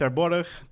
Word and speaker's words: Terborg: If you type in my name, Richard Terborg.